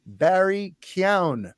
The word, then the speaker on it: Keoghan: But speaking of antagonists, in The Batman, we got that little glimpse of Barry Keoghan's, by the way, that is how it's pronounced, even though it's spelled Keegan, whatever. Yeah. Our friend of the show, Luke Tuckshear, over in the UK, did a little research and found out that it's pronounced Barry Keoghan.